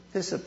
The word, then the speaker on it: hyssop: And the Roman soldiers took the hyssop plant, some people pronounce it hyssop plant, and they dipped it in sour wine, which must have tasted like yuck.